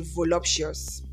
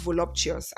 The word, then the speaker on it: voluptuous: Now, the first time I knew about this word, or all along I know about this word, I used to say voluptuous. And I realized that it's not even pronounced voluptuous. It is pronounced voluptuous. And voluptuous is spelled V-O-L-U-P-T-U-O-U-S, voluptuous.